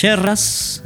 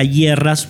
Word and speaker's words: yerras: Nosotros nos vamos a despedir con la última suite, que arranca con una narración llamada Cuando llegaban las yerras, ¿eh? o las hierras, si se quiere. Eh, la pronunciación sería hierras, pero nosotros acá le decimos yerras.